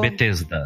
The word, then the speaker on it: Bethesda: E vamos falar um pouquinho então da é Bitenza, né? Que fala? – Bethesda. Bethesda. Bethesda.